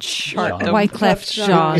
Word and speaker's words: Wyclef Jean: That would be, which band gave rapper Wyclef Jean his first taste of chartdom? – Oh. – I'm not sure if I've said his name right, but Wyclef Jean.